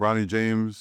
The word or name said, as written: Bronny James